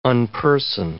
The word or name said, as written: unperson